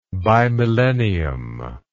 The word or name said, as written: bimillennium